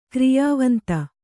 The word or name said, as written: ಕ್ರಿಯಾವಂತ